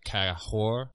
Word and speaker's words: Kagor: It's spelled K-A-G-O-R, but the G's, the sound that it makes is kind of like an H sound, so it's kind of like Kahor, but she's telling me that that is not correct, that I'm not saying the H sound correctly.